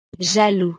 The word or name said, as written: jaloux